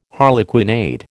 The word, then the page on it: harlequinade: American English